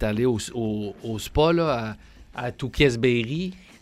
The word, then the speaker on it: Tewksbury: J'entendais l'histoire en fin de semaine d'une jeune peintre, artiste peintre de 15 ans qui vient de la région de Stoneham, Tewksbury, dans le coin de Québec. Ouais. Là. – C'est Tewksbury qu'il faut dire, j'ai jamais su comment le prononcer.